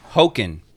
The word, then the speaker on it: Hoechlin: Hoechlin, as in Hoboken without the bow.